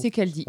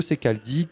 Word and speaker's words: Ceccaldi: et également donc Théo Secardi. Je sais pas vraiment comment ça se prononce, je pense que je sais pas trop. Secaldi. On est bien, ok. – Euh, Théo Secaldi qui porte également des projets de fusion.